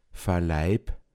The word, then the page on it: nit vakiifen kunna: Pinzgauer Mundart